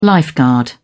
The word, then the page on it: lifeguard: British English